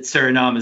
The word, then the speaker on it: Suriname: He's an international player from, and I'm going to totally butcher this country's name, but it's like Suriname or Suriname. I'm not sure how it's pronounced. – Suriname. – But it's in Suriname. – Thank you.